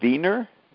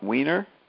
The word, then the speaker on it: Wiener: You say you pronounce it, what you say the W like a V like the Germans. I've never heard that, but that's how you do it? Norbert Wiener, not Wiener?